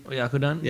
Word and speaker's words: Oyakodon: Oyakodon? – Yeah.